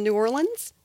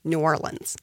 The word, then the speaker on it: New Orleans: So, I'm from a little bit down the road from New Orleans, which is how you say it. – Say it again. – New Orleans. – New Orleans. Yes. That is how Louisiana says it.